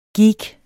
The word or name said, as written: geek